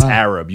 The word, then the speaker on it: Arab: It's Arab. You said it wrong, guy.